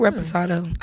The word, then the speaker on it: Reposado: I like Reposado. I think I pronounced that correctly, right? – Mm-hmm.